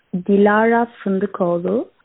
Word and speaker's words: Dilara Fındıkoğlu: And the designer that you were trying to pronounce the name of is Dilara Fındıkoğlu. She is a Turkish-born designer, and that's a Turkish name.